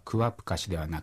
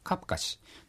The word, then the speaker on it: カプカシ: 次はですね、私が歩くという場合はクワプカシではなく、サル方言ではカプカシという発音になってしまいます。